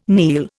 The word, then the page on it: kneel: Indian